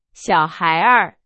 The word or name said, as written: 小孩儿